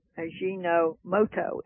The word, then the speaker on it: Ajinomoto: A-J-I-N, Ajinomoto, M-O-T-O, Ajinomoto.